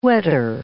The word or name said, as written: wetter